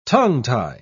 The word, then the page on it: tongue-tie: British English